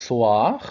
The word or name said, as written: soir